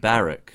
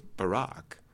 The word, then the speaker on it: Barack: Interestingly enough, in the UK, frequently Barack Obama is pronounced Barrack.